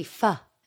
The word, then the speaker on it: pho: Yeah, I've made a pho this evening. Oh, I know, lovely. I just also pronounced that wrong. Sorry about my terrible pronunciation. It's meant to be pho, isn't it? – Pho.